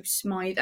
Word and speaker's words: Smythe: But, I mean, they do have a reasonable tight end when it comes to Smythe, I believe it's pronounced, because it looks like Smith with a Y in the middle.